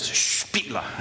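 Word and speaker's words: Špela: and she always explained her name pronounced like this, Spila. And I said, okay. I said, okay, it's Sheila with a P instead of an H.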